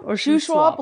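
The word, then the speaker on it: Shuswap: Shoe Swap Lake, okay? – Yeah.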